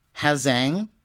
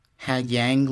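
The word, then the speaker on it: Hà Giang: Hazang, Hagyang Loop. Supposed to be. – I think both of those ways are correct.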